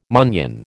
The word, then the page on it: munnion: American English